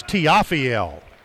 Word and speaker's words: Tiafiel: It rhymes with Raphael. It's Tiafiel. But we'll call him T.